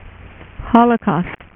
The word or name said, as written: Holocaust